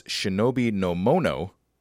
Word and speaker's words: shinobi no mono: These two words read in Japanese pronunciation read as shinobi no mono. As Chinese pronunciation, they read ninsha. – Okay. Oh. So it's actually the Chinese pronunciation that gives you the name ninja.